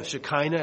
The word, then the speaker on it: Shekinah: So you find that this Shekinah of glory, or Shekinah as some pronounce it, coming back.